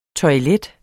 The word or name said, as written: toilettet